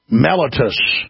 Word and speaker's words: mellitus: How do you pronounce that? Diabetes mellitus. – Mellitus. – Mellitus. Diabetes mellitus.